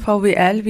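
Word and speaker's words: VWLs: Ausgesprochen klingt VWL wie die Abkürzung für das Studienfach Volkswirtschaftslehre. Aber das L haben wir ersetzt durch LS, die weibliche Mehrzahl aus Französisch. Und das klingt ausgesprochen halt VWL wie das Studium, aber L in Französisch steht für die Pluralität und auch die feministische Perspektive darin.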